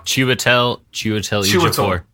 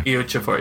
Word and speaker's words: Chiwetel Ejiofor: but I believe it's Chiwetel Ejiofor. – Chiwetel Ejiofor. Yeah. – Ejiofor, yes.